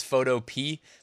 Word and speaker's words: Photopea: By the way, I know some people say it's Photopea. For some reason, my brain just cannot wrap itself around saying Photopea, so I have to call it Photopea and pronounce that A at the end.